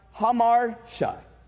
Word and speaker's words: hamartia: The most common one of all five is this first one, hamartia. Okay, that's how you say it. H-A-M-A-R-T-I-A.